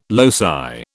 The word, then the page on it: loci: British English